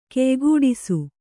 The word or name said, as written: ಕೆಯ್ಗೂಡಿಸು